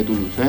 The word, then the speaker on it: Toulouse: ¿Tu luz cómo suena? Con acento en la U. Un EP de cinco canciones, cuatro de ellas con letra, una quinta instrumental. Un muy lindo disco de Toulouse.